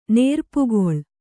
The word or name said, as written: ನೇರ್ಪುಗೊಳ್